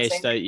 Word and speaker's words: Steidl: Exceptional, uh, produced by is it Steidl? Is that how you pronounce it? Yeah, Steidl. Yep, yep.